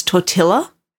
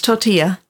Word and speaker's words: tortilla: pronounced tortilla with a hard L sound. – Where's the L? – He said, sorry, it's tortilla. And she said, no, it's tortilla.